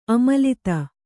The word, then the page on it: ಅಮಲತೆ: amalate